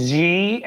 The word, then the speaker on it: Xi: I said Xi, I got it. Awesome. – Xi, like almost a J sound.